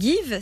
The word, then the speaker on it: Geev: Par exemple, sur la plateforme Give, ça s'écrit G-E-E-V, euh, vous pouvez donner ou recevoir gratuitement un peu tout type de produits, notamment des fournitures scolaires.